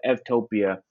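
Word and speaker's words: Eutopia: So we just put the EU on topia, uh, and EU in Greek is pronounced, uh, the V is pronounced as a V. So instead of U, it's Ev. And so Evtopia is a, is a good place.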